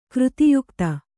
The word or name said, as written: ಕೃತಿಯುಕ್ತ